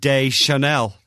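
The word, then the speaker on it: Deschanel: Oh, one Zooey Disc Channel fan's been on. She's not annoying, and FYI, it's pronounced Zooey De Chanel.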